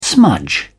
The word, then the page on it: smudge: British English